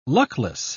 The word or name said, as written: luckless